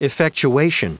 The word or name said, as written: effectuation